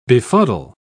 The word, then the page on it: befuddle: British English